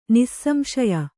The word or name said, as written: ನಿಸ್ಸಂಶಯ